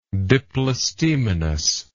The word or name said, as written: diplostemonous